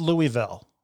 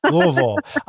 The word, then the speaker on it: Louisville: And that was called Yes, Louisville. Say it wrong. – Yes, I know. It's, you know what? – Louisville. – You're supposed to say it like you got, you know, like you've been drinking bourbon.